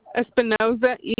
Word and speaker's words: Espinoza: J-U-A-N-A. – What's your last name? – Espinoza, E-S-P-I-N-O-Z-A. – E-S-P-I. – N-O-Z-A.